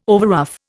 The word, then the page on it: overruff: Indian